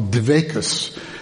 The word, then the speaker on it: dveikus: It's what in Hebrew / Yiddish they would call dveikus, which is the Yiddish pronunciation of the Hebrew dveikut, which is clingingness, connectedness to God.